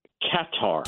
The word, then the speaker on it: Qatar: So give me your pronunciation again and the airline's pronunciation again. Qatar? – Qatar. – Qatar. Qatar. Emphasis on the can.